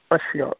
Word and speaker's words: Ashil: Ashil, A-S-H-I-L. – Okay, it's A-H, how do you say it? – I'm not A-S-H-I-L.